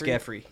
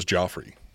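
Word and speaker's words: Giuffre: Is that how you pronounce it? It's Geoffrey? – It's Geoffrey, yeah. – I have never heard that said out loud. I always thought it was Joffrey.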